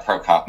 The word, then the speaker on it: Prokop: Yeah, Prokop.